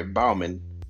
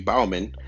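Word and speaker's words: Baumann: I mean, you get Patrick Bonham. And I'm sorry if I butchered his name. I'm sorry, Bauman. Patrick Bauman.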